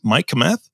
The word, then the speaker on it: Kmeth: And your guest is Mike Kmeth.